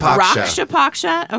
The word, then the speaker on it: Raksha Paksha: And then we've got Raksha Paksha. Am I saying that right? – Raksha Paksha. – Okay, so they're like rock.